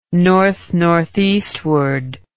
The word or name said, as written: north northeastward